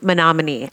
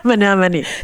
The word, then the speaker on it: Menominee: but it's pronounced Menominee. And then of course, yeah, that's exactly what, yeah. Menominee. Menominee.